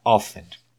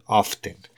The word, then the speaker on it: often: El segundo adverbio que quiero que abordemos sería often. Hay otros que lo pronuncian often y las dos formas son válidas, con T y sin T.